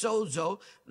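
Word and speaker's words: sozo (σῴζω): That's how you say it. It's sozo.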